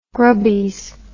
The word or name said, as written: grubbies